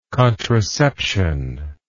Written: contraception

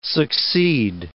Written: succeed

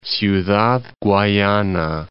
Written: Ciudad Guayana

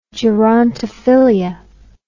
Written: gerontophilia

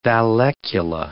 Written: vallecula

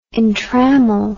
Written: entrammel

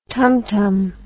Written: tumtum

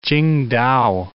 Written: Qingdao

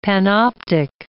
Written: panoptic

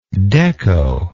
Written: dekko

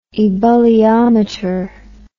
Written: ebulliometry

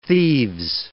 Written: thieves

